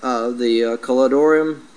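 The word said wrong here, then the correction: Chedorlaomer: or wait, how do I say that? Caledolomer. There we go.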